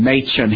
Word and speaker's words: Machen: Well, John Gresham Machen, and by the way, we're going to talk about Dr. Machen. That's the way you say the last name, Machen.